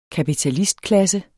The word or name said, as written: kapitalistklasse